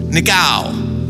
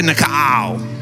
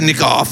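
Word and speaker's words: Nikao (νικάω): Which actually is pronounced Nike, which comes from the Greek verb Nikeo, pronounced Nikol. Nikao. How do you say it? Nikao. America, Nikau. Everyone say Nikau. Don't say Nikoff, say Nikau.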